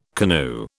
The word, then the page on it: canoe: British English